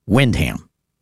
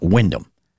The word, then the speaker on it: Windham: But in New Hampshire, the people in Windham like to pronounce it Windham and not Windham.